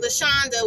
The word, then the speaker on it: LaShonda: Or if you just can't pronunciate LaShonda, which sounds like Honda, with a L in front of it, then you could just say, what?